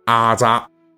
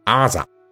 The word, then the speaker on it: 腌臜: 呃，本期我要介绍的呀，是一个在《水浒传》里边出现的频率非常高的骂人词汇，这就是“ 阿扎”，也可以读作清音“ 阿扎”。